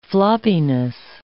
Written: floppiness

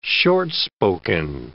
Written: short-spoken

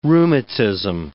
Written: rheumatism

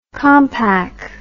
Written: compaq

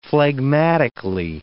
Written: phlegmatically

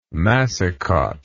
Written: massicot

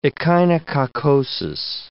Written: echinococcosis

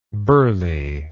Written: Burleigh

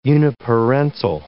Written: uniparental

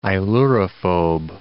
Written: ailurophobe

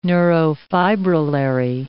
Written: neurofibrillary

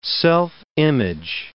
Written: self-image

